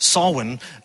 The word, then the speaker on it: Samhain: So Halloween originated with an ancient Celtic tradition called, it's spelled Samhain, but apparently it's pronounced Samhain,